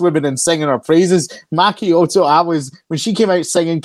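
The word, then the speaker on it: Maki Itoh: I can't ever pronounce her name right, but Maki Ito, yeah. – Maki Ito.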